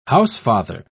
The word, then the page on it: housefather: British English